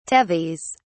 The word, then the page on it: tethys: British English